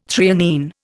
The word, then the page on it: threonine: Indian